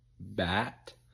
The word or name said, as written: bat